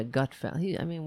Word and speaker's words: Gutfeld: So, for example, the Greg Gutfeld, is that how you say his name? Yeah, Gutfeld.